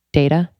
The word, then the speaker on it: Deida: D- I'm going to say this wrong. Deida. I did it. – It's yeah. right.